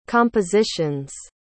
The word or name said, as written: compositions